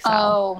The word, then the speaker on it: Salle: Yeah, because I think it's French in origin and it would just be like Sal. – Oh. – But it's spelled S-A-L-L-E, which kind of threw me off.